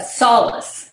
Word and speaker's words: Solace: So just so I have this right, you're the chief marketing officer of Solus. Did, did I say that right? Uh, solace. – Solace. Solace. I'm sorry.